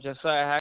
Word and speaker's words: Josiah: Josiah Hacker.